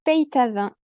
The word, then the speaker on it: Peytavin: Alors, il se prononce en réalité Peytavin. C'est un nom qui est lozérien. Voilà, donc on prononce le Y et la plupart des gens qui s'appellent, qui ont ce nom de famille, viennent effectivement de la Lozère, tout comme moi.